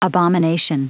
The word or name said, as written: abomination